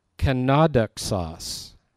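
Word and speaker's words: Kenodoxos (κενόδοξος): Kenodoxos is the way it's pronounced. I've pronounced it kenodoxos, and I think that sounds much more brilliant, but I'm wrong.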